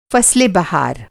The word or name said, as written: बहार